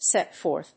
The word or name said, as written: set forth